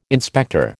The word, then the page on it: inspector: American English